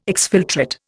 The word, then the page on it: exfiltrate: Indian